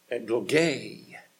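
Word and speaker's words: eklogē: If you need the word, if you want to spell it and then look it up, it's E-K-L-O-G-A with a long mark over it. Egloge. E-K-L-O-G, long A.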